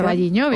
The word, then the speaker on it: O Carballiño: O Carballiño, no lo sé pronunciar. O Carballiño, bien.